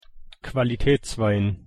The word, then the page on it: qualitätswein: German